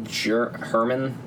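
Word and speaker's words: Germán: So there's a guy on the team, Herman. German Rubstav or something. – Herman. – That's how you pronounce that thing.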